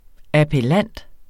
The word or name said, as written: appellant